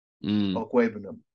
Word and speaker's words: Okwuegbunam: His name is Albert Okwuegbunam. – Beautiful pronunciation.